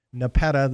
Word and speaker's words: Nepeta: Nepeta, that's spelled N-E-P-E-T-A. Nepeta. Okay? – All right.